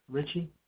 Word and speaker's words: Richie: Mike Richie. Last name is spelled R-I-C-H-I-E.